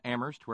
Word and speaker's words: Amherst: And then for today, thank you very, very much indeed to Terry up in Amherst, where they don't pronounce the H.